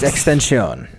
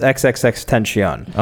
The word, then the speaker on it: XXXTentacion: And she got into controversy last week because she did a pretty good joke about the rapper XXXTentacion. – It's Extension. – Is it? – Tension. Yeah. – But if you read it phonetically, yes. XXXTentacion.